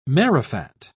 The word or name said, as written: marrowfat